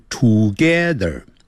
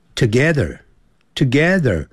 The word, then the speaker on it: together: I live together，不要念成 together，要念 together 那个 T-O-G-E T-H-E-R 有个 R 结尾，所以在美式的发音呢要卷舌的。